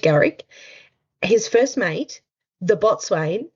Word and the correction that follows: boatswain: No, bosun. – Bosun? Is that how you say it? – Yeah, bosun. – Boatswain. – No, mate, bosun. – Bosun. – That's right, isn't it, Sarah? – Is it bosun? – It's bosun, isn't it? – I don't know.